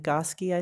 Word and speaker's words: Nagoski: Okay, question is, the book Come As You Are by Emily Nagoski. Did I say that right? I feel like Nagoski, I think.